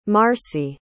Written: marcie

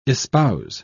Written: espouse

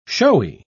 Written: showy

